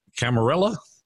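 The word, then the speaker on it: Ciaramella: And all of a sudden, she blurted out his name, which is Eric Camarella. Correct? Is that how you pronounce it? – Yeah, that's what we understand it to be.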